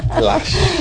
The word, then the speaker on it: Lush: Después también quería hablar aquí del famoso Luz, Las, o como se pronuncie. Y es que... – ¿Cómo se escribe? – L-U-S-H. – Lax. – Si quieres te paso el link de compra. – Lax. – Vale. El Lash. – Lash.